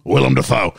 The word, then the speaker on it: Willem Dafoe: I think I've got you going William Dafoe, George going you going William Dafoe, George going William Dafoe, Julian going William Dafoe, me going Willem Dafoe.